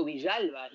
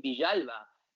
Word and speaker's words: Villalba: He's Argentine born, and he pronounces his name Villalba. And I broadcasted it every single goal that he scored, Tito Villalba. And I would say it like that.